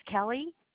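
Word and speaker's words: Kellie: My name is Kelly. – It's K-E-L-L-Y? – Actually, it's K-E-L-L-I-E.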